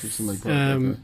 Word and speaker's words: Houston Lake Park: It's at, I believe I'm pronouncing it correctly, Houston Lake Park. – Oh, okay. – Yes. – Houston Lake Park.